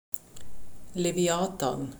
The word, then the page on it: Leviathan: German